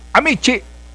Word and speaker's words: amici: And kung nanood ka noong Amici, ang sabi ni Justice Panganiban, the syllable, the stress in the word is in the first syllable. It's Amici.